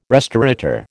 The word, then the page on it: restaurateur: American English